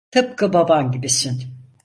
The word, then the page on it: baban: turc